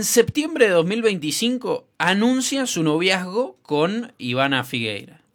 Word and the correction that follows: Figueiras: Figueiras, ahí lo dije bien.